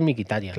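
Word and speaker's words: Mkhitaryan: Dice, me parece que Tatitov, eh, que cómo se pronuncia Mikitarian, que desde el Borussia no sabe, es así, ¿no? Mikitarian. Sí, yo creo que es Mikitarian.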